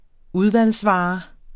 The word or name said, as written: udvalgsvare